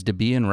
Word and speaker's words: Debian: And I know that there are two people, right? Who, Deborah and Ian, is that what? – Yes. – Okay. So is Debian right? – Sure.